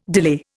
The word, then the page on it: delay: Indian